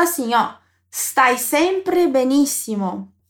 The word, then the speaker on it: stai: Aqui eu chamo a tua atenção para a pronúncia da letra S no começo da palavra stai. A pronúncia é bem sutil, porque esse S, ele é mudo, na verdade. Então, nada de colocar um som de vogal antes dele, hein?